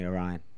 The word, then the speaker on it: Orion: I said his name wrong the first time as well. It's definitely Orion. Like Orion's belt, no? – Yeah, that doesn't matter.